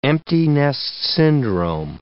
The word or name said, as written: empty-nest syndrome